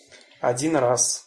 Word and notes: odin raz: Russian

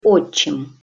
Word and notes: otcim: Russian

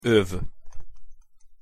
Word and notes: ov: Hungarian

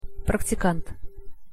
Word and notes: praktikant: Russian

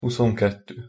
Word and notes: huszonketto: Hungarian

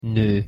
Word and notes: no: Hungarian

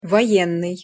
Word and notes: voennyj: Russian

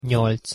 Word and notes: nyolc: Hungarian

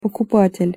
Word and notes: pokupatel': Russian

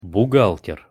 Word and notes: buhgalter: Russian

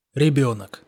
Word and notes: rebenok: Russian

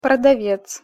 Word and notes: prodavec: Russian

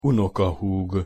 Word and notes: unokahug: Hungarian

